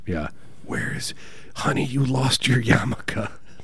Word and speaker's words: yarmulke: Yarmulke. – Yarmulke.